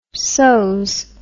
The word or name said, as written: sos